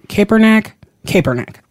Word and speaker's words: Kaepernick: I don't even know how you say his name. Kaepernick.